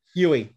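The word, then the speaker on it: Yueh: Yui.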